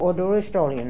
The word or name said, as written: oduristolnian